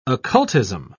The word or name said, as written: occultism